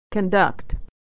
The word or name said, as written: to conduct